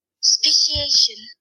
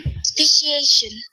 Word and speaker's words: speciation: Well, hey, thanks for the text, John, because now we can actually find out how do you pronounce it. – Speciation. – There you go. I was right.